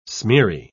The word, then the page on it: smeary: British English